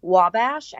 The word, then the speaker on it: Wabash: And in 2012, she captured the title of Miss Wabash.